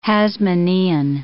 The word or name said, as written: Hasmonaean